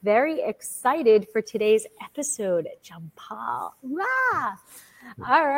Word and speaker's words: Jampal: So yes, we are all over the world today, and we are talking about Jampal, and I hope I am pronouncing that correctly.